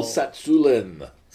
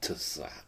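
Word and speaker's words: Tsatsouline: Pavel Satsulin. – Satsulin, yeah. They have some... – With a silent T at the beginning. So it's not Tsatsulin, it's just Satsulin.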